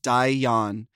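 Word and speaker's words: da iawn: After the lip sync, she can be heard saying Dayan in the background, pronounced Dayan. It means well done.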